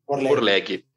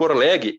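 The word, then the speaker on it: Orlegi: E esse clube hoje, ele é do grupo Orleg, como é que eu pronuncio? – Orleg, exato. Isso. E o grupo Orleg, ele também é dono do Atlas.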